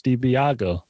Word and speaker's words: DiBiagio: So I was like, yeah, oh, corruption, DiBiagio. But it's of course DiBiago.